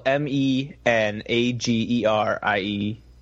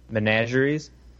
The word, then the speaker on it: menagerie: Uh, I'm gonna butcher what it, how it's actually pronounced, but spelled M E N A G E R I E. Menagerie. Menageries.